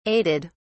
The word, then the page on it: aided: British English